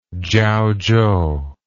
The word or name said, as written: Chiaochou